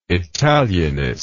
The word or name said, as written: italianist